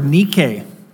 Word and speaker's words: Nike: There's a Greek word, Nike, transliterated the same way, N-I-K-E, Nike.